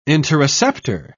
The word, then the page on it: interoceptor: British English